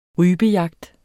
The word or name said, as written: rypejagt